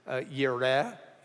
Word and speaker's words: Jireh: and Jireh is Yireh in the Hebrew, and it just means that God will provide.